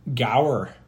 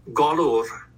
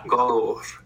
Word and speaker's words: golwr: G-O-L-W-R. – Gower. – That's pretty close. Galur. – Ooh, okay. Yeah. – Galur.